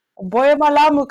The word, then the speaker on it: Boye malamu: Is that how you say it in Lingala? – It's close. – We butchered it. – It's close. It's really very proper, right? Boye malamu is very, very proper.